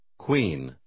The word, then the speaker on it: queen: Часто буква U произносится как W, когда она стоит сразу после Q. Прочитайте номер 10 после диктора. – Queen.